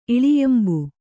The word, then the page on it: ಇಳಿಯಂಬು: kannada